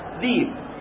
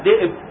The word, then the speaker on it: ذئب: ذيب، ولكن عندما تنطق بها ذئب تكتبها ياء وتضع فوق الياء هذه القطعة تبين أن هذا الياء هي همزة فتنطقها ذئب، وإلا هي مكتوبة ذيب على لغة التسهيل.